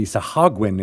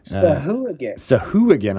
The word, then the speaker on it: Sahuagin: We used to be Sahagwin is how I thought it was, but you pronounce the ooh as a ooh again. – Sahuagin.